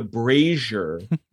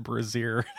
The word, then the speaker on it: Brazier: I pronounced it brazier. – Brazier. Yeah, no, I don't think you're getting that out of B-R-A-Z-I-E-R.